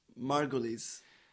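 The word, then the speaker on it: Margolyes: Miriam, M-A-R-G-O-Y-L-E-S, is apparently pronounced... – Margulies.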